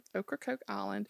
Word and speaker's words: Ocracoke: And I looked up how to pronounce that, and that's how you pronounce it, Ocracoke Island.